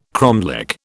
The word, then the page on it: cromlech: British English